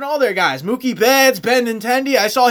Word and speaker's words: Benintendi: Is that how you say his name? – Benintendi.